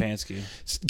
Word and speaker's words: Stefanski: Kevin Stefanski? – Stefanski.